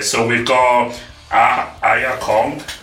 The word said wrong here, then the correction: Aja Kong: Aja. – Oh, no. You just corrected him.